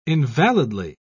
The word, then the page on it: invalidly: British English